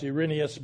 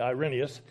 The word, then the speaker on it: Irenaeus: It's sometimes pronounced Irenaeus, but I say Irenaeus.